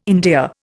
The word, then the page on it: endear: Indian